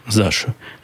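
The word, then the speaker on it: Zasha: Заша так и пишется, как слышится, через Z. Z-A-S-H-A. Заша. Ну, как Саша, только почему-то с Z.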